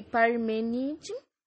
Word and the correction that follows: Parmênides: Deixa eu ver se eu falei certo. Ó, Heráclito, do time mobilismo, e Parmênides, do time imobilismo. Eu não tenho certeza se eu falei certo, mas essa última foi certa, eu dei uma olhada.